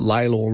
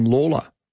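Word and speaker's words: Lalor: I mean, the thing is the Lawler family is still around, but the suburb that's named after them is, everybody pronounces it Laylor rather than Lawler.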